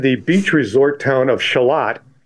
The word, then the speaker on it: Charlotte: For one thing, takes place in Shalott, which now, is spelt like Charlotte.